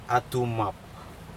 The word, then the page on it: atuMaP: Aneityum